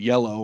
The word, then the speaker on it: Jelle: It's spelled J-E-L-L-E, but it's called Yellow or something like that because it's from the Netherlands.